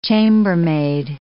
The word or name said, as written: chambermaid